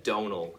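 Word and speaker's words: Domhnall: But it's Donal is how I pronounce my name, which is like tonal but with a D instead of a T, and the M is just there to confuse Americans. – And I have like one pair of like jeans, like a couple T-shirts and a sweater.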